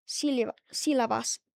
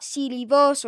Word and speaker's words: sílabas: It's not sílabos or sílabas. – And it's sílabas.